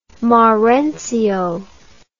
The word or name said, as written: marenzio